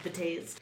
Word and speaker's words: Betazed: I think it's actually Beta Z. – But occasionally... – English people would call it Beta Z. – Maybe it's actually meant to be pronounced Beta Z. – I betazed. – Yeah, there you go, betazed. That's actually, I believe it's pronounced betazed.